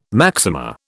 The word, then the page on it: maxima: British English